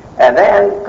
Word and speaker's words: adhan: Adhan is just a different accent on the first letter that makes it a instead of u. So it's adhan, a long a at the end, adhan, from udhun, ear.